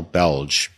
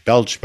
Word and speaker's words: Bellg: Folks, we're speaking today with Al Belge. Belge, by the way, is spelled B-E-L-L-G. I think it's out of Central Europe, right? – That is almost correct, yes.